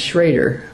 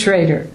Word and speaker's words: Schrader: Vic was German, grew up in a German community, and the Schrader, the O-E in Schrader, Schroeder was an umlaut, and that was pronounced Schrader. Well, the umlaut got dropped, but the pronunciation didn't, but Vic's philosophy on his name is he'll answer to either one.